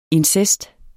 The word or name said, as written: incest